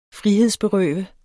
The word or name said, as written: frihedsberøve